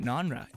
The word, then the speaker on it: nonra: Oh, I love that, nonra. It rhymes with genre, but it's non-genre.